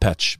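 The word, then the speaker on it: Peć: The Serbs pronounce it Pech, but in Albanian it's pronounced Peja.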